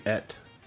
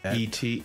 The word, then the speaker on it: E.T.: What about the movie Et? – What? – Hmm? – E.T.